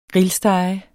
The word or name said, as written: grillstege